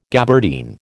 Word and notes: gabardine: American English

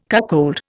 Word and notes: cuckold: Indian

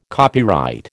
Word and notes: copyright: American English

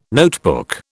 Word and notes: notebook: British English

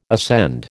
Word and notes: ascend: American English

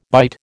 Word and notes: bite: American English

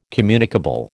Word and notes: communicable: American English

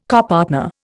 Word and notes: copartner: Indian

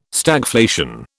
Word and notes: Stagflation: British English

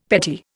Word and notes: petit: Indian

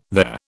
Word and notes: there: British English

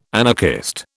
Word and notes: anarchist: British English

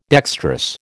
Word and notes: dextrous: American English

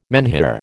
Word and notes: menhir: American English

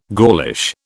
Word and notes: Gaulish: British English

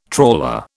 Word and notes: trawler: British English

